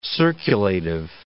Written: circulative

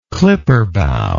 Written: clipperbow

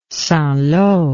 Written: St Lo

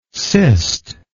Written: cistic

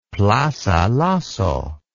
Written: plaza lasso

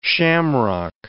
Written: shamrock

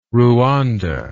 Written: ruanda

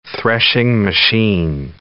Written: threshing machine

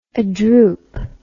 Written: adroop

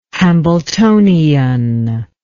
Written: hambletonian

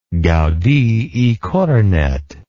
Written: Gaudí i Cornet

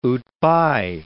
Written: outbye

